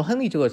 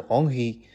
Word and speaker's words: Henry: 那么亨利这个字呢，如果你正确读音是什么？是 Henry。那么这个字呢，既不是昂利，也不是亨利。